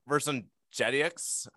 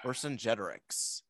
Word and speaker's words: Vercingetorix: I just love at the end of the scene that just kind of stare that Mark Antony gives to Vercingetorix. I'm pronouncing that wrong. – Vercingetorix.